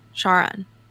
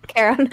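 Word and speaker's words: Charon: Is that am I pronouncing that right? Sharon. – Karen. – Karen. Yeah.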